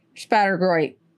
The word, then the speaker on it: Spattergroit: Spattergroit.